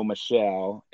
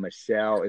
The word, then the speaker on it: Michell: My Facebook is just my name, Antonio Michell, and Michell is spelled M-I-C-H-E-L-L.